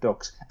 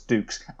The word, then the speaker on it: Dux: Fucking hell, like even in the movie, there's a line saying, "Oh, you must be Frank Dux," and he corrects him. No, it's Dukes, and you, and of course it's you, get the name wrong. – it's spelled D-U-X.